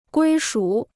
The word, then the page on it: 归属: guī shǔ